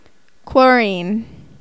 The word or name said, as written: chlorine